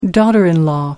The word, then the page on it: daughter-in-law: American English